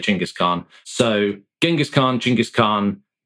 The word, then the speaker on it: Chinggis Khan: I think if you want to, you get Chinggis Khan is how most scholars say it properly, but I'm a pragmatist in this regard, rather than strictly politically sensitive and correct.